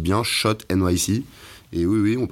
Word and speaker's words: Schott: Vous prononcez sûrement euh, Scott, mais en fait on dit Shot.